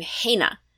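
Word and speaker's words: Hainna: They just started saying ain't it so fast that it just became Haina.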